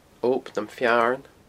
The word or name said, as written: Ob nam Fearn